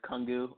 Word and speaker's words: Kungu: Last name is Kungu, almost like Kung Fu, just with the GU. So it's a little bit easier to pronounce.